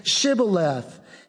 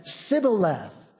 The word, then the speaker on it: Shibboleth: So the test is, if you say you're not an Ephraimite, say Shibboleth. And he said, Sibboleth. And for he could not frame or he could not pronounce it right.